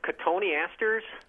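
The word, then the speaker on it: Cotoneaster: I believe it's pronounced Cotone asters.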